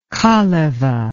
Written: kaleva

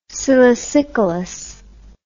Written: silicicolous

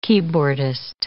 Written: keyboardist

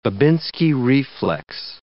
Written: Babinski reflex

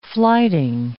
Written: flyting

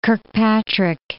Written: Kirkpatrick, Mount

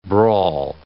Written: brawl

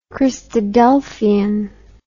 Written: christadelphian

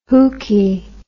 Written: hookie

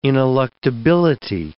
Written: ineluctability

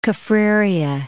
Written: Kaffraria

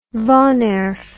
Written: woonerf

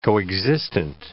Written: coexistent

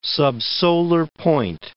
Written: subsolar point

